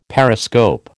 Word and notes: periscope: American English

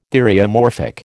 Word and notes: theriomorphic: American English

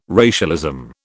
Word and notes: racialism: British English

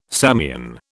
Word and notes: Samian: British English

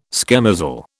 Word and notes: schemozzle: British English

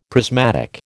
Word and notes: prismatic: American English

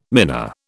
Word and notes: mina: British English

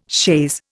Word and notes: chaise: Indian

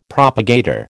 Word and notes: propagator: American English

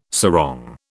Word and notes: sarong: British English

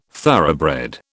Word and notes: thoroughbred: British English

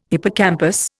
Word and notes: hippocampus: Indian

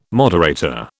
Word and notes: moderator: British English

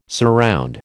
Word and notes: surround: American English

